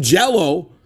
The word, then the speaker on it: Jellÿ: Yes, but it's still, yeah, it's spelled Jellÿ, but it's pronounced Jell-O still because there's those umlauts above the Y.